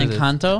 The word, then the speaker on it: Encanto: El Canto. I don't know what Encanto? – It is.